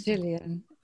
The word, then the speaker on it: Gillian: Gillian. With a soft G, Gillian.